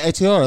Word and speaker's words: Aotearoa: And forgive me if I say this wrong. Aotearoa? – Yes. – Is that how I mean, I don't know. – It means, doesn't it mean love or hello or family or something like that? – I thought it was a city. – Aotearoa. – It's the New Zealand greeting. – Yeah, Aotearoa, that's what you say.